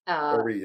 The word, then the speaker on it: Horry: That's Horry for those of people who don't know South Carolina. – So. Yes, Horry, Horry or Oree, but I think it's pronounced Oree. – Oree, yeah.